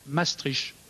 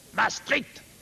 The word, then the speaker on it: Maastricht: Ceux qui sont plutôt pour disent, même si ce n'est pas à mon avis la prononciation exacte, Maastricht. C'est doux, c'est musical. Ça entraîne, ça ne fait pas peur. Et ceux qui sont plutôt contre disent plutôt Maastricht.